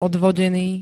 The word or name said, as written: odvodený